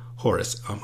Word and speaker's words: Horus: Horus, H is silent.